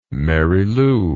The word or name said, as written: Mary Lou